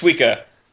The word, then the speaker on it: Suika: No, Sukiya, he's not dead. She kept like, he's dead! – It's Suika. – Oh yeah, I always say her name wrong. Suika.